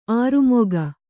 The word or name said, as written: ಅಱಮಗ